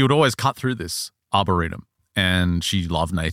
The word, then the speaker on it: arboretum: What is an arboretum? I don't know that arboretum. – Word. Arboretum? Is that how you pronounce it? – Yeah,